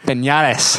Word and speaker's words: Pinellas: I think that's how you say it. – Pinellas? I'm like 99% positive. – But because it's in Florida and there's a double L, I want to do the Y.